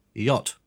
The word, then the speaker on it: yacht: How do you say this? Yacht. – Yeah, it's got to be one of the wackiest words.